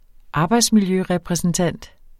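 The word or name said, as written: arbejdsmiljørepræsentant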